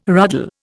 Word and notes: ruddle: Indian

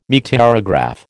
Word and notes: meteorograph: American English

